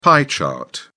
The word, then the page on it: pie chart: British English